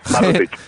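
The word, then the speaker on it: Barrutik: He visto también que estaba una publicación, el Baturrik, no sé si se pronuncia así exactamente. – Baturrik. Sí, Baturrik en euskera es desde dentro.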